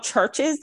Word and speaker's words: CHVRCHES: But we both are really into this, like, Scottish pop sync band called Churches. They spell it with a V, so C-H-V-R-C-H-E-S.